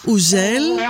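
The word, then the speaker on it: Hugel: Ο Χιουγκτζέλ, ο Ουζέλ, έτσι προφέρεται, έρχεται στο Θέατρο Βράχων και ευκαιρία να έρθετε κι εσείς Αθήνα να τον δείτε από κοντά.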